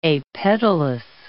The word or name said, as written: apetalous